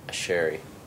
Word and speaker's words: Asherie: Asherie, am I saying that right? – Asherie. You got it. – Okay, Asherie.